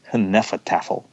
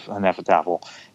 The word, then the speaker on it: Hnefatafl: I was basing it on a historical Viking game, which is pronounced something like Hnefatafl.